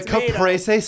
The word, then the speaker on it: caprese: It has the white cheese with the green leaf and the caprese salad? – Yeah, not...